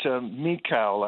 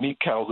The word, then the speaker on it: Michal: Well, I think that Michal, I think, is the way that we pronounce her name, Michal, the daughter of Saul.